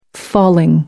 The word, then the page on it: falling: American English